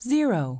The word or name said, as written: 0